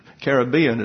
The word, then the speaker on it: Caribbean: But let me tell you, one of the problems in the Caribbean, which is the corrected pronunciation, is the fancy people will say Caribbean, but it's number two with Webster. Caribbean is number one.